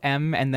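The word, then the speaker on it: MØ: I've been told it's a capital M and then O with the slash through it. I've been told it's pronounced Ma.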